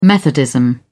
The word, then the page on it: methodism: British English